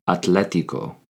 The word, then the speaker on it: atlético: Athletic in Spanish is atlético. Atlético, spelled A-T-L-E-T-I-C-O, accent on the E.